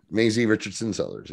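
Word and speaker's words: Maisie Richardson-Sellers: Did I say it right? – Maisie Richardson Sellers. Yeah. – Yay. Good job.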